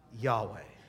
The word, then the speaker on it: Yahweh: That's because observant Jews do not speak God's personal four-letter name, which is translated YHWH, and probably pronounced, what? Yahweh.